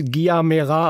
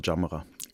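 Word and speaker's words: Gyamerah: Und wenn man es einmal weiß, ist glaube ich auch gar nicht so schwer. So richtig ausgesprochen wird mein Name Jamra.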